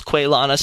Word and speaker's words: Quelana: The only real difference besides the G is that she doesn't have the same emphasis on the end of her name like Quelana has, so I just roll it all together as Quelana.